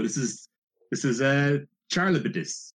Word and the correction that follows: Charybdis: Charlebidis. No, Charybdis. – It's Charybdis. – It's Charybdis. – Charybdis. There we go.